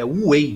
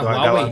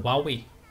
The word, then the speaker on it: Huawei: A gente não fala o H, é Huawei, velho. – Da, não é Huawei, do... Huawei.